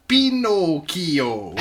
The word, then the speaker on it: Pinocchio: So, for those of you who aren't familiar, Dallin, Pinocchio, pronounced Pinocchio, is a fictional character. – Why is he said it that way? – I'm just giving you the correct Italian pronunciation, just so you know.